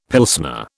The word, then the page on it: Pilsner: British English